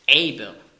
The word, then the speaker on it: Heber: Drum, Heber spricht man aus auf Hebräisch, Eber.